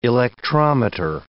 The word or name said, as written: electrometer